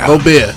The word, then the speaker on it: Gobert: Rudy Gobert, did I pronounce it right? – Gobert.